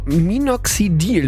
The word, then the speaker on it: Minoxidil: Minoxidil, Lukas, das habe ich richtig gesagt? – Das hast du gut gesagt, ja.